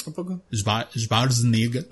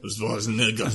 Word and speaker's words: Schwarzenegger: así se pronuncia. Schwarzenegger. Schwarzenegger. Schwarzenegger.